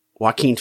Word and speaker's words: Joaquin: Joking Phoenix. Joaquin Phoenix.